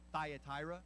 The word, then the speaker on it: Thyatira: Now, we would always say, phonetically, Thyatira. Can you all say Thyatira? Right, but doesn't Thyatira just sound so much more authentic, right?